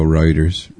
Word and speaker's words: Reuters: I'd definitely go Reuters. Reuters would be like R-O-O-T. – How do you get Roy out of R-E-U? – I'm just fucking with you.